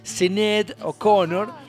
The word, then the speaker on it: Sinéad O'Connor: Y aparece una figura realmente importante de la música como Sinéad O'Connor, con acento en la E, Sinéad O'Connor, artista irlandesa que lamentablemente, tristemente hoy falleció a los 56 años.